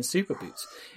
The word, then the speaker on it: Superboot: And Super does take a lot of the emphasis in Superboot in his three syllables.